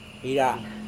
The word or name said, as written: ira